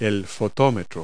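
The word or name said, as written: exposure meter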